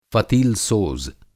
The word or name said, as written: फ़तीलसोज़